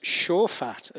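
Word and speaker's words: shaphat: But so this is one of the words the Bible uses a lot, which is pronounced shofat, as you can see.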